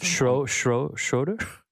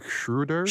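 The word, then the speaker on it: Schröder: Well, I already literally asked. – Schroeder. – It's like Schroeder. – Schroeder. Okay. I do want to be respectful. – Yeah, I'm. I don't have a respectful person. I don't have the embouchure to say to pronounce an umlaut properly, but I will work on it over the course because I think it's important.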